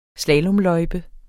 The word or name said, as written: slalomløjpe